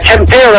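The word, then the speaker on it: Tampere: They are in, I think it's called Tempera. Is that how it's pronounced? – Tempera, I believe.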